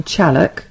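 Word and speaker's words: Challock: Have I said that right? – Yeah. – Chalock. – For once. – It could be Chalock, you see, because no. You never know. – Well, some locals do call it Chalock, actually.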